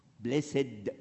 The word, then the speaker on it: blessed: بليسد دي تعلمتها في السبعينات في انجلترا. اي كلمه جايه ادجكتيف لازم الاي دي تتنطق، لكن الكلمه اللي جايه باست ما تقولش الاي.